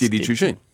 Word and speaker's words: Didi Chuxing: I mean, Uber sold off to I can't pronounce Didi Chuxing. – Didi Chuxing. Did I actually get the pronunciation? – Pretty much.